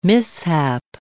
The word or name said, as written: mishap